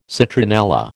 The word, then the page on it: citronella: American English